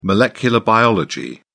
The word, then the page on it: molecular biology: British English